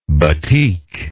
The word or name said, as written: battik